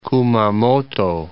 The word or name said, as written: Kumamoto